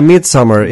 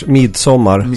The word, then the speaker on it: Midsommar: Is that how you say it? Midsommar, the movie that you watched? – Yeah, Midsommar, English. Midsommar, Swedish.